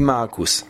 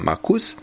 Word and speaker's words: Markus: Et justement, du coup, tu as tendance à accentuer. Sur la deuxième syllabe, hein, comme tu ferais en français. Or, en allemand, tu verras, tu vas apprendre à fabriquer ça. C'est plutôt la première syllabe qui est accentuée. Et donc, le prénom de ce jeune garçon, c'est Markus, et pas Markus.